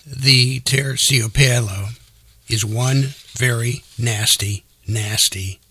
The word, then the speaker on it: Terciopelo: And the name of the snake is called a Terciopelo. I know I butchered that, but let me try it again. It's Terciopelo. It's also known as the fur de lance.